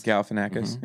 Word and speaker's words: Galifianakis: Zach Galifianakis. – Galifianakis.